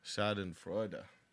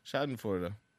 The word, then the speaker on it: Schadenfreude: Schadenfreude. Okay. Schadenfreude.